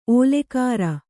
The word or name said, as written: ಓಲೆಕಾರ